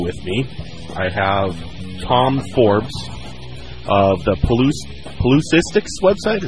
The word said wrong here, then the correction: Pelusitics: Is that how you say it? – Pelusitics. – Pelusitics. Sort of like politics. – Exactly.